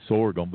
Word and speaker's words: sorghum: Plus, right there in Blairsville, we had the Sagarum Festival, so uh, well, some yeah. people say I say it wrong. It's sorghum, but anyway, sorghum, sorghum.